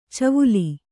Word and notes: ಚವುಲಿ: kannada

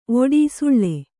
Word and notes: ಒಡಿ ಸುಳ್ಳೆ: oḍi suḷḷe

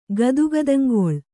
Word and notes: ಗದುಗದಂಗೊಳ್: kannada

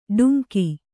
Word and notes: ಡುಂಕಿ: Kannada

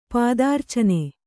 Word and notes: ಪಾದಾರ್ಚನೆ: pādārcane

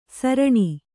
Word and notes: ಸಾರಣಿ: sāraṇi

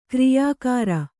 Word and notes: ಕಾರ್ಯಾಗಾರ: Kannada

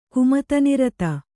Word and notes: ಕುಮತನಿರತ: kumatanirata; kannada

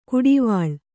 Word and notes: ಕುಡಿವಾಳ್: kuḍivāḷ

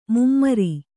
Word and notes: ಮೈಮರೆ: kannada

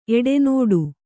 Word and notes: ಎಡೆನೋಡು: eḍenōḍu

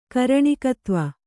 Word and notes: ಕಾರಣಿಕತ್ವ: kāraṇikatva

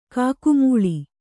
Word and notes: ಕೊಕ್ಕೆಮೊಳೆ: kokke moḷe

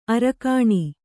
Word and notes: ಅರಗಿಣಿ: aragiṇi

